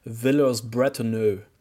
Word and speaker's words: Villers-Bretonneux: The first clash between tanks was at Villers-Bretonneux near Amiens in April of 1918. – Sorry, Jesse, as the Australians pronounce it, Villers-Bretonneux.